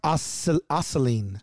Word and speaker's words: Aceline: Oh, pronounced Aceline. – Aceline, like Aceline. Gasoline.